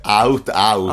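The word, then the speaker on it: aut-aut: Qui tra ultimatum ultimamente veramente, poi cosa vuol dire lot-out? Come si pronuncia? – Out-out.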